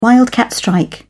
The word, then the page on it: wildcat strike: British English